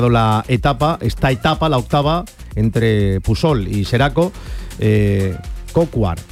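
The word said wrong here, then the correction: Coquard: el... seguramente no se dice así, pido disculpas, el ciclista francés. – Brian Cocard, que es precisamente el que está hablando, que imagino que no sé si se le entiende o no.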